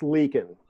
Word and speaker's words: Leakin: Is it Leakin? – It's Leakin. – Leakin. Mm-hmm. So Corporal Eric Leakin, can you speak to, it seems like he was just interwoven into your life several times.